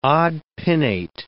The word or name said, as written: odd-pinnate